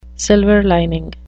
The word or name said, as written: silver lining